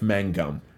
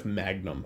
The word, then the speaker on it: Mangum: I can't not pronounce his name Jeff Magnum. – I know. – Whenever I see it, I see him as Jeff Magnum.